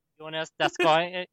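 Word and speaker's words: Joonas Donskoi: Giannis Donskoy. – It's pronounced Kraken. No, you have to say it how that football player said it. – Jonas Donskoy. – Jonas. He said Jonas Donskoy.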